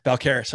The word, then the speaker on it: Balcarres: Balcarres.